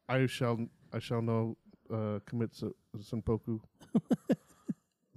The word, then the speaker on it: seppuku: Second one was seppuku. There's no N in it. Seppuku.